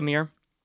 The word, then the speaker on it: Meir: And then MS-15 was just back in September on September 25th, 2019, Jessica Meyer, Meir, forget how to say her name. I think it's Meir. Jessica Meir.